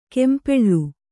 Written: ಕಂಪಳ

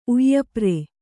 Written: ಉಯ್ಯಾಪರೆ

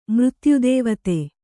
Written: ಮೃತ್ಯುದೇವತೆ

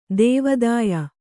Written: ದೇವಾದಾಯ

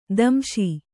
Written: ದಂಶಿ

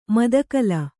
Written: ಮದಕಲ

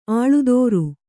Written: ಅಳ್ದರಿ

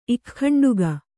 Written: ಇಖ್ಖಂಡುಗ

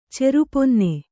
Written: ಚೆರುಪೊನ್ನೆ

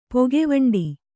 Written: ಪೊಗೆವಂಡಿ

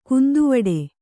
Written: ಗಂದವುಡಿ

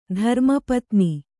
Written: ಧರ್ಮಪತ್ತನ

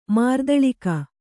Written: ಮಾರ್ದಳಿಕ

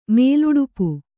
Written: ಮೇಲುಡುಪು

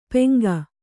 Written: ಪೆಂಗ

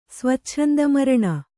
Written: ಸ್ವಚ್ಛಂದಮರಣ

